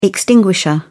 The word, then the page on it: extinguisher: British English